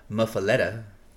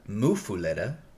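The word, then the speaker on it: muffuletta: Google says muffaletta, and then there's also one that says people in Louisiana in certain areas call it a mufuletta. – That sounded the same. – Foo, long U, short U.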